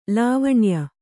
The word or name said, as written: ಲಾವಣ್ಯ